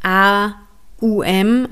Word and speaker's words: Om: Diese heilige Silbe aus den drei Buchstaben A, U, M, also es ist ja eigentlich, es wird immer O, M geschrieben, aber eigentlich geht es darum, A, U, M zu rezitieren. Aum.